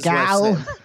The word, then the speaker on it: Seagal: We decided that this was going to be Seagal. Why do I say it like that? That's Seagal. Seagal.